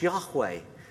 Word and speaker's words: Yahweh: This week, Philip shared with the staff team how the Hebrew word for Lord, which we pronounce Yahweh, in accordance with how it's spelt, is never actually written down in full, including its initials.